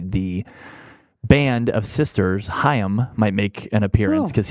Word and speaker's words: Haim: Haim. – See, I've always said Haim. – I've always said Haim. – And I only learned it because it's like La Haim.